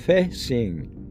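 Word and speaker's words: feixing (飞行): Symbols in the description pronounced feixing, feixing, feixing, spelled F E I X I N G, means flying the title.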